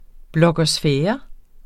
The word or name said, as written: blogosfære